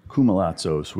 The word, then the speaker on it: Koumalatsos: Welcome to the stage, Nick K, and I'm going to let you pronounce it in the most authentic Greek fashion for the listener. – That would be Koumalatsos. – Koumalatsos, which sounds easier to say than what it looks like,